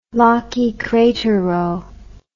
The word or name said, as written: Laki crater row